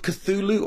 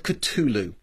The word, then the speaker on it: Cthulhu: He is best remembered for his, there are different ways to pronounce this, I'm going to say it twice, there's Call of Cthulhu or Call of Cthulhu, and that might sound familiar to you.